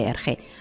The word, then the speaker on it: Hergé: Fue un historietista belga, más conocido por el seudónimo artístico de Hergé, por ser esa la pronunciación de sus iniciales invertidas, R.G., en francés.